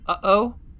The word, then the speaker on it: uh-oh: You have to say, uh-oh, and have that pause in there. That pause is the glottal stop.